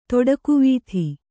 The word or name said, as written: ತೊಡಕುವೀಥಿ